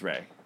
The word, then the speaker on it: Ralph: Ra- it's pronounced Ray. Ray. – Well, it's spelled Ralph.